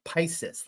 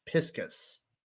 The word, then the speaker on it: piscis: Now, this is pronounced vesica piscis. Now, I want to pronounce it like Pisces, like Pisces, but apparently it's piscis.